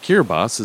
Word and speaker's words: Kiribati: Kiribati is actually the local pronunciation of Gilbert. The Kiribati people adopted Latin script for their writing and phonetically spell words with some differences in how they're spelled in English. They also write the S sound as T-I, which lends confusion when one applies American pronunciation to their words.